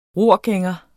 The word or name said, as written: ror